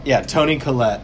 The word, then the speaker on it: Toni Collette: Toni Collette.